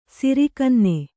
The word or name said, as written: ಸಿರಿಕನ್ನೆ